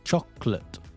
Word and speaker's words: chocolate: It's not chocolate, it's not chocolate either, it's chocolate, chocolate. Okay, so at the end of a noun or end of an adjective, A-T-E is pronounced as ut, just to make it more accurate. Okay, when A-T-E is at the end of a verb,